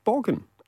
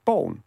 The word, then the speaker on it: Borgen: I thought, okay, Phil has watched perhaps Borgen, like you pronounce it, right? – Oh. – And then I said, oh, well done on pronouncing Danish. You must have been watching a lot of Danish Bone, which is how we pronounce it.